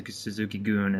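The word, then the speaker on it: Suzuki-gun: They Suzuki Goon is how they pronounce it.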